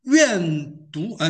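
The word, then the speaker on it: 怨讟: 愿读啊，那个有边读边有半读半，这个字没有错。按照那边读，我真的去查过，真是念作愿读，就是愿抱怨神的意思，就愿读神和摩西说：“ 你为什么把我们从埃及领出来，使我们死在这旷野当中？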